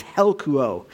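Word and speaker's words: helkuo (ἑλκύω): Now, the Greek word here for the word drawn is the word helkuo, and I know I'm pronouncing that correctly. It's helkuo.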